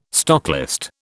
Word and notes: stocklist: British English

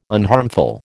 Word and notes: unharmful: American English

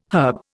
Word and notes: herb: Indian

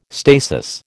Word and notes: stasis: American English

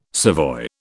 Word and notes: savoy: British English

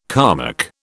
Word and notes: Kalmuck: British English

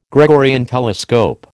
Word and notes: Gregorian telescope: American English

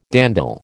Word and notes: dandle: American English

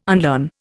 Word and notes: unlearn: Indian

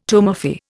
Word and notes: tumefy: Indian

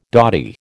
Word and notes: dotty: American English

